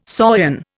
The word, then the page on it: saurian: Indian